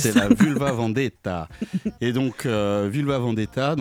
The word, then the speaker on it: Vulva: Et donc, je vous le disais en introduction, ce soir, c'est Flo, Tiff Lam et Vulva Vendetta. Je ne sais pas si je le dis bien, je dis Vulva.